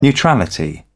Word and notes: neutrality: British English

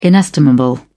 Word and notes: inestimable: British English

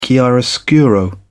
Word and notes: chiaroscuro: British English

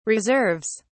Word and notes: reserves: British English

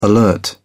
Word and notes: alert: British English

